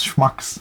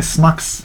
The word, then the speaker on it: schmucks: I also like how you can't pronounce schmucks. – Schmucks? – You kept saying smucks.